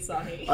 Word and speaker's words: Asahi: While we're having I'm so sorry, but definitely Asahi.